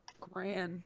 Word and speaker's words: grand: It's gran. – It is gran, right? – I mean, technically it's grand, but no one pronounces the D.